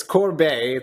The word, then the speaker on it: Corbeil: It's Corbeil.